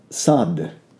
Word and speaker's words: Sadh: Siddhi comes from the root Siddh, S-I-D-H, which is a weak form of Sad, S-A-D-H, with a long A, and we'll get to that because it's the root of sadhana.